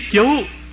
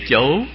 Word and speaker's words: chủ: Bên ông Hoàng Xương Hãn thì để mấy chú, bên nay thì để mấy chủ. Bởi vì chữ nôm nguyên là chữ chủ, mà có thể đọc là chú nữa, nhưng ở đây thì ghi nguyên là chủ.